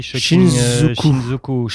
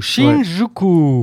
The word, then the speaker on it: Shinjuku: Ah oui, Shinjuku. Shinjuku.